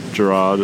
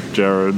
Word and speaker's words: Gerard: I'm like, no, it's not Gerard, it's Gerard. I'm like, oh, but it's not spelt like it's spelt Gerard.